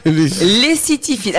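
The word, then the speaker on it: lécythiphile: Le lessitifile. – La lessive. – Le quoi? – Non. – Le lessi quoi? – Lessitifile. Alors attention, ça s'écrit L-E-C-Y-T-H-I-P-H-I.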